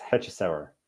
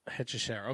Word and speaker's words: Hechicero: How do you say his name? – I've from other people I've listened to say it, it's Hechicero. – Hechicero, okay, because I try and like speak it the way it's spelled, and I sound like an idiot.